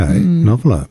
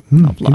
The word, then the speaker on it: NOVLR: Noveler. Noveler.